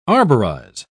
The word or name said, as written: arborize